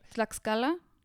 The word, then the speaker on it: Tlaxcala: Because I can't remember, you said them earlier, Tlaxcala? – Yes, thank you.